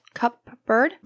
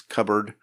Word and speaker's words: cupboard: Cupboard? – They're pronouncing the P instead of it being silent. I mean, you don't really pronounce the P in that word. It's cupboard.